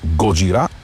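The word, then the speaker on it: Gojira: Годжира, това е японската дума за Годзила. Знаем, че групата започва именно по това име. Позволявам си да произнасям с френското ударение на последната седичка – Годжира.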